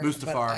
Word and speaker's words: Mustafar: Mustafar.